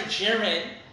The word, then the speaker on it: Jiren: Say his name correctly, Jiren. – Now let's go back. – Yeah, I is a double E.